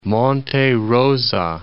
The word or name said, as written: Rosa, Monte